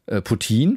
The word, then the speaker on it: Poutine: Ja, vermutlich, das wird irgendwas, vielleicht spreche ich es auch falsch aus, P-U-U-T-I-N geschrieben. Und Google sagt, Poutine, französische Aussprache tatsächlich, ist eine in Kanada populäre Fastfood-Spezialität.